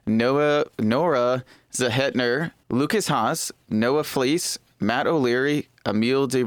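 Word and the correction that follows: Emilie: It's Emily or Emil? – I think it's just Emily. – Okay, fine. It's Emily.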